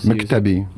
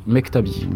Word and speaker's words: Mektabi: Et il semblerait que j'ai écorché le titre de, l'extrait qu'on vient d'entendre. Hein. – Mektabi. Mektabi. La, destinée.